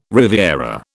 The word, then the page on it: riviera: British English